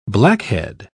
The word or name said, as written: blackhead